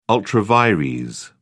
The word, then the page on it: ultra vires: British English